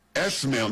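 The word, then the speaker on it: Esmaeil: Esmail, not even the Ishmael. It's Esmail Baghava.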